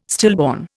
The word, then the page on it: stillborn: Indian